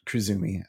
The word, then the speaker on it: Krizumi: He's with Krizumi. Krizumi. I feel like I crushed it before, and now I'm nervous about it. Well, welcome, Luke. Did I say the name right? – Yes. Thank you.